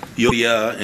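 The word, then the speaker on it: Euodia: Yeah, it's Eudia and Syntyche.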